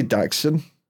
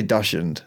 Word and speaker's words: Dachshund: Oh, I mean, you meant to say Dachshund. – Dachshund. – Is it? – Yeah. But I just say dashend.